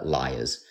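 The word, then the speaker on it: Lyre's: So the company's called Liars, which is spelled L-Y-R-E apostrophe S.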